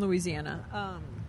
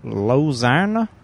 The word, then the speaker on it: Louisiana: What about the one that's in Louisiana? – Lozana? Never heard of her. – That's not how they say it, ever.